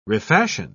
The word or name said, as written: refashion